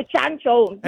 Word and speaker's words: Shang-Chi: Así dijiste tú, Shang-Chi. – Es que yo lo dije como se pronuncia. Nosotros que somos chinos.